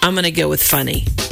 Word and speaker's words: Funnie: And that's, I think that's F-N-N-I? – Correct. It's pronounced Funny. It's spelled F-U-N-N-I-E. – F-U-N-N.